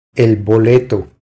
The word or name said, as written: El boleto